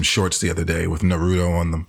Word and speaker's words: Naruto: Yeah, especially because you don't even know that it's pronounced Naruto.